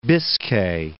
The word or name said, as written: Biscay